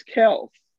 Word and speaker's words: Kelce: It's actually Travis Kelce. Not Travis Kelce, as people have been calling it.